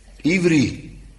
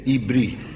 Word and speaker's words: Ivri: Ivri zijn de Hebreeën. Ibri, met een B, hè? Heber, maar de H valt weg, hè? Die spreken ze niet uit.